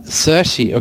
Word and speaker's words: Saoirse: Cersei, okay, also ah.